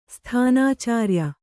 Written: ಸ್ಥಾನಾಚಾರ್ಯ

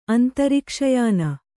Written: ಅಂತರಿಕ್ಷಯಾನ

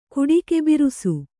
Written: ಕುಡಿಕೆಬಿರುಸು

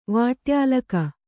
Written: ವಾಟ್ಯಾಲಕ

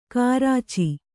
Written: ಗೋರೋಚ

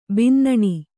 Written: ಬಿನ್ನಾಣ

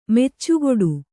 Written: ಮೆಚ್ಚುಗೊಡು

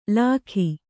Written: ಲಾಖಿ

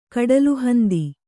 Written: ಕಡಲುಹಂದಿ

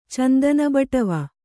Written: ಚಂದನಬಟ್ಟವೆ

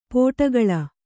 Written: ಪಡುಕುಳಿ

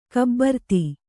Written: ಗಭೀರತೆ

